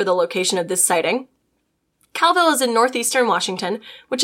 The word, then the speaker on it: Colville: Sorry, it just told me how it's pronounced. Pronounced Calville. Sorry, I'll go back. However, when going to high school in Calville, Washington, I did have a friend who saw cryptid once.